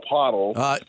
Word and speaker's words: Poeltl: It's Poeltl or Poeltl. – Poeltl. – Poeltl. – Yeah, there's no R, but somehow there's an R in the pronunciation, Chuck.